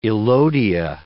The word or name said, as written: elodea